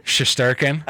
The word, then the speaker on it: Shesterkin: Shesterkin. – Okay. – That's how you say it.